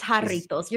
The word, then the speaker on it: Jarritos: But and it's Jarritos. You're still saying it wrong. That's why I rolled my eyes, because we're literally like 3 years in and you still can't say it right.